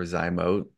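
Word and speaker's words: Zymōt: I think technically the name is Zymo or Zymote. – Oh, like a silent T. How annoying. – Well, no, it's like the O, it's got two dots over it. But because the whole industry, when it rolled out, everyone called it Zymot and that's what stuck.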